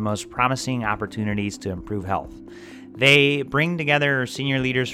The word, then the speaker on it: HLTH: HLTH, pronounced health,